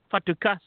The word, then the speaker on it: Fatukasi: The name is Fatukasi. Fatukasi. You got to pronounce it in Italian.